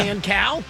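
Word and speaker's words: Kan Cao: K-A-N is the first name. – Okay. – The last name is C-A-O. How do you pronounce that? Cow? – Yeah. – Can Cow? – Sure.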